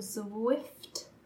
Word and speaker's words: Zwift: I've heard that this thing called Zwift.